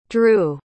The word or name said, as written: dru